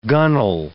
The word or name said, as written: gunwale